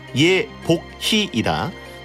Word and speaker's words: 예복희: 이복희라고 하셨는지 예복희라고 하셨는지. – 그러면 이거를 정확한 단어로 이거 배디가 읽어드리도록 하겠습니다. 읽어주시죠. – 네. 네. – 1번 예니콜은 줄타기 전문이다. 2번 네. 예니콜 본명은 예복희이다.